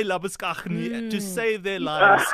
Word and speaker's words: Labuschagne: So in Australia, they say Labuschagne.